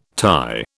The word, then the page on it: Thai: British English